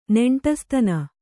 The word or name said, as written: ನೆಂಟಸ್ತನ